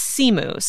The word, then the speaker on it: Simu: So I have been saying Simu for a long time. – So have I. – But he says Simu. So I am trying to, again, correct my pronunciation.